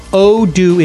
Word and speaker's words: Odoo: Well, Odoo is here to help. That's spelled O-D-O-O.